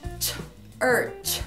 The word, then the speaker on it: church: E lembrando que as palavras que também terminam com CH também tem som de ch. Por exemplo, igreja, church. Church. Church. Qual which, which.